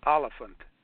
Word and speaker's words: Oliphant: William Oliphant. It's like elephant, only spelled with an A. Oliphant.